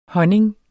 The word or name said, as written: honning